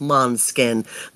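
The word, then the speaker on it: Måneskin: It's spelled Maneskin, but pronounced Monskin.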